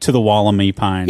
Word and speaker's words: Woolamai: It's not the Woolamai or Wollamai. – Nope. – It is the Woolamai. – That is the Australian pronunciation, which I think is the best we can do.